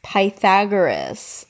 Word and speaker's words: Pythagoras: With Greek philosopher Philagoras. Philagoras? Wait, I literally, where's my phone? Pythagoras? I don't even know. H-A-G-O-R-A-S. – Pythagoras.